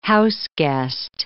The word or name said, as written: houseguest